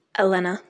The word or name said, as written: Elena